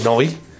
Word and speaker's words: Neu: Aunque Noi tuvo un éxito comercial mínimo, por cierto, se lee Neu, nuevo en alemán, se escribe Neu, se lee Noi.